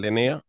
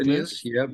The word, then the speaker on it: Linnaeus: Carl Linnaeus, is that the correct pronunciation? – Yeah, Linnaeus, exactly. – Linnaeus, yeah.